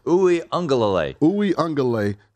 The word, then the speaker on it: Uiagalelei: I wouldn't be surprised if DJ Uiagalelei. How you say it? – Uiagalelei.